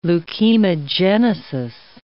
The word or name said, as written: leukemogenesis